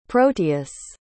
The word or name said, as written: proteus